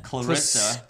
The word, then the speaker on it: Clarissa: So it's Clarissa.